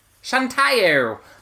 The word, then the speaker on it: Shantae: Shantae. – Shantae.